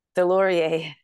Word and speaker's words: Deslauriers: Deslauriers.